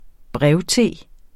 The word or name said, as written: brevte